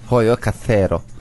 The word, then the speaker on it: Hoyocasero: Hoyocasero. Hoyo diye okunur. Bak, Hoyo. Hoyocasero.